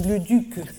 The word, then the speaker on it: Leduc: She did a wonderful job of pronouncing Le Duc. We practiced that.